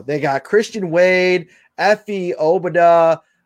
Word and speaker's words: Obada: So kind of like Qdoba. I keep wanting to say Qdoba, but it's Obada. Okay. – Obada.